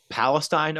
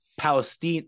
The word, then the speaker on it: Palestine: The kids even know that this is not pronounced Palestine, Ohio. It's pronounced Palestine.